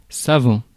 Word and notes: savant: [sa.vɑ̃]; France

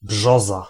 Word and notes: brzoza: /ˈbʐɔza/; /ˈbʒɔza/

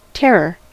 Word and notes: terror: /ˈtɛɹɚ/; /ˈtɛɹə/

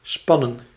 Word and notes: spannen: /ˈspɑ.nə(n)/; /spɑ.nə:/

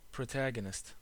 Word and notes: vedette: France; [və.dɛt]

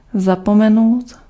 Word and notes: dimenticare: /di.mɛn.ti.ˈka.re/

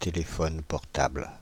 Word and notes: téléphone portable: Paris; /te.le.fɔn pɔʁ.tabl/

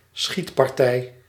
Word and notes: tir: France; /tiʁ/

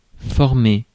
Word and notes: former: [fɔʁ.me]

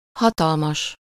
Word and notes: gigantesque: [ʒi.gɑ̃.tɛsk]; France

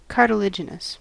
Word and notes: cartilaginous: American English

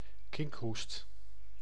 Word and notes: coqueluche: [kɔ.klyʃ]; /kɔk.lyʃ/

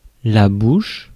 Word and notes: bouche: /buʃ/

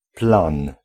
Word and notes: plan: [plɑ̃]; France

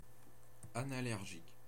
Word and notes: anallergique: IPA: /a.na.lɛʁ.ʒik/; French

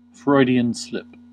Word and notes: Freudian slip: /ˌfɹɔɪd.i.ən ˈslɪp/; /ˌfrɔɪd.ɪ.ən ˈslɪp/

